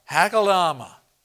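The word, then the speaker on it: Akeldama: in their proper tongue and the way to pronounce this, you need to put an H in front of that. Uh, Hakeldama.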